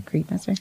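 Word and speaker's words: Kriegsmesser: Did I say that right? Kriegmesser. – Sounds right to me.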